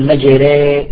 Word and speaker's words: مجراها: تقول مجري مجري، تزنها على وزن زيد، مجري، هكذا.